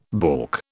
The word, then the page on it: balk: British English